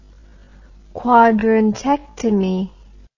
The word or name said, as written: quadrantectomy